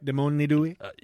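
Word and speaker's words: Dèmoni 2: All right, that's how you say it, right? Demoni due? – That is correct, yes.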